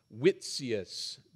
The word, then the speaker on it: Witsius: And I think that his name is pronounced Witsius. W-I-T-S-I-U-S.